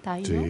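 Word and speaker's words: Taíno: ¿Taino? – Sí, sí.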